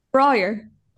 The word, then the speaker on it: Brallier: Brallier.